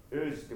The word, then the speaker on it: urge: ウージって言葉はちょっと発音しにくいですので、曖昧母音のウだと思ってください。ウージ。そんな感覚でお願いします。